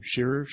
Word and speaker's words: Shirer: and I don't have any idea how to pronounce his last name, Shirer, I would say.